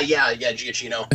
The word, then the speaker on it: Giacchino: Austin, in the comments, mentions that he thinks the music was done by Michael Giacchino, however you say his name. – Yeah, Giacchino.